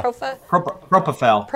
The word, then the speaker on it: propofol: He was only on one drug that they had determined from his autopsy lab reports, and it was the propofol. – How do you say it? – Yeah. – Propofol.